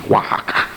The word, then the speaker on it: Oaxaca: Finally, they get the van fixed and he's leaving Monday morning to go back to Oaxaca. That's where he lived. It's not spelled like it sounds, believe me. Oaxaca.